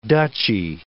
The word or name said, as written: duchy